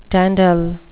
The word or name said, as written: dandle